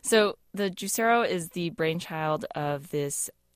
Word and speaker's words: Juicero: A lot of people were saying Juicero. – Juicero, yeah. – It is Juicero.